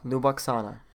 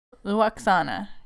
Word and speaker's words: Lwaxana: Luwaxana. – Luwaxana. Yeah, they separate the L and the W.